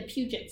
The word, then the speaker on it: Puget: The Puget Sound. – I don't know how to pronounce that. Well, I didn't know how to pronounce that G.